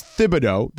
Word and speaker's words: Thibodeau: Do you pronounce the H in Thibodeau? – It's Thibodeau, but they call him Tibbs. – Yeah, I was going to say, man, I didn't know that there was an H pronouncing that. – Yeah, it's Thibodeau. That's that's how you pronounce his last name, but his nickname is Tibbs.